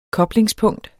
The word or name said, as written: koblingspunkt